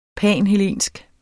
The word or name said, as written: panhellensk